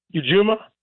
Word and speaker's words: Ujima: And, you know, it's like it's like the Kwanzaa principle, Ujima.